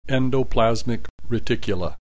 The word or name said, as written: endoplasmic reticula